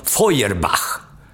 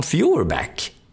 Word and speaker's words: Feuerbach: Han var tysk ättling och han uttalades i Tyskland som Feuerbach, men i Amerika som Fuhrbach.